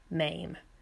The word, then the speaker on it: Mame: The correct pronunciation of Jerry Herman's musical, originally starring Angela Lansbury in the titular role, is MAME.